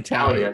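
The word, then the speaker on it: Taulia: Talia. It's only Talia.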